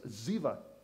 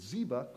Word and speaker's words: Ziba: In Hebrew, the letter for B, Beit, can be pronounced with a V. So the B, it's Ziba, could be Ziva. So Ziba and Ziva are the same name.